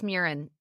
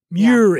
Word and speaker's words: Murin: Patty Murin, is it Murin or Murin? – It's Murin. – Murin. – Yeah, it rhymes with urine.